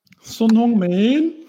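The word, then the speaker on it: Son Heung-min: Son hong min. The O is long like an open. Så det ska vara alltså son. – Son. – The EU is a short U like in put.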